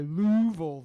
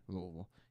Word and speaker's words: Louisville: They play Louisville this week. I'm kidding. I know how to say it the right way. Louisville.